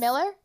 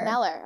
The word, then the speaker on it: Mueller: Miller? – Meller. – Meller, okay, cool. – It's spelled M-U-E-L-L-E-R, so it looks like Mueller, but it's pronounced Meller.